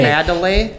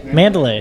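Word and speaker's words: Mandalay: Mandolay. – Mandolay.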